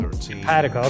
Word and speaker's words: Empatico: How do you say it? – Empatico? – Empatico, okay.